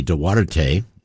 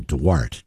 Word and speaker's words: Duarte: The family name is Portuguese, so it would be Duarte if we were doing Portuguese pronunciation, but the family did pronounce the name Duarte, D-W-A-R-T would be the pronunciation, even though it's spelled D-U-A-R-T-E.